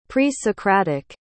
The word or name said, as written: pre-socratic